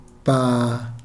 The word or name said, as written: paa